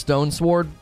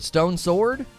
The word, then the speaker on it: Stone Sword: Lona doesn't even pronounce Stone Sword properly. Is it Stone's Word or Stone Sword? Is it Stone Sword? Oh, Stone Sword. I always say Stone's Word. It is. It's a little stone sword, isn't it?